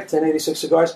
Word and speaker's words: Ten86: 1086 Cigars? – Correct.